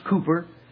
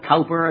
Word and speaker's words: Cowper: And in that village lived, and I may not get the pronunciation you want, William Cowper, properly pronounced Cooper, but I was always brought up in school to call it Cowper, and many of you were too, so we'll settle for Cowper.